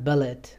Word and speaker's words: Bullitt: Motorola has teamed up with Bullitt Group, not Bullitt, but Bullitt. It's spelled B-U-L-L-I-T-T, and they've entered into a partnership with this company that essentially creates phones that essentially are drop-proof and shock-proof.